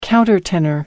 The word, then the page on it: countertenor: American English